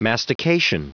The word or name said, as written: mastication